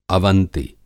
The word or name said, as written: अवंति